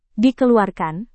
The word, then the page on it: dikeluarkan: indonesian